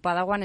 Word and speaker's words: Padawan: ¿Qué significa Padawan? Se pronuncia así, ¿verdad? – Así es, Padawan significa aprendiz.